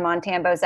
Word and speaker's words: Montambo: Interestingly enough, most people think that it's African, and it's not. It's actually French-Canadian. It's Montambo, but you know.